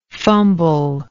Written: fumblingness

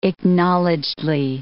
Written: acknowledgedly

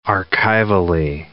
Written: archivally

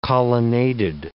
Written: colonnaded

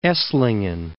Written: Esslingen

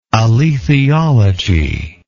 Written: alethiology

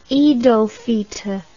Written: eid ul fitr

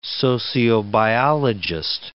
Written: sociobiologist

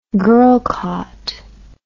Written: girlcott

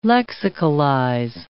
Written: lexicalize

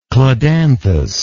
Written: cladanthous

